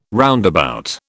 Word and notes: roundabout: British English

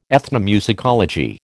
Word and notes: ethnomusicology: American English

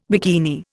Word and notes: bikini: Indian

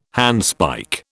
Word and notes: handspike: British English

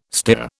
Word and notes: stere: British English

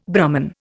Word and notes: Brahman: Indian

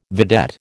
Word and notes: vedette: American English

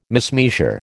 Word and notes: mismeasure: American English

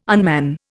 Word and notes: unman: Indian

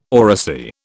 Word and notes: oracy: British English

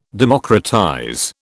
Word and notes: democratize: British English

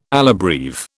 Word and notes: alla breve: British English

